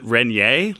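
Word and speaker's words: Rainier: And he is constantly drinking Ranier beer. – But somebody in that show pronounces it Renier, like the French way, apparently.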